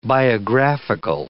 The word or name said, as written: biographical